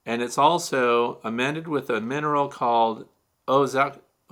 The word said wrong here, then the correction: ozokerite: Now I'm going to spell it for you because I might be mispronouncing it. It's O Z O K E R I T E.